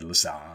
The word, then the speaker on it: Lassabe: Lassabe speakers. See, I even did that. Lassabe.